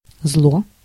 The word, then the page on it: evil: American English